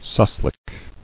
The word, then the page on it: Souslik: sous·lik